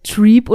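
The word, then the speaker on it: Trepe: Ist mir egal, ob ihr sie vielleicht Treep oder sowas aussprecht, für mich ist es Quistes Treppe.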